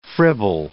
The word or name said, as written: fribble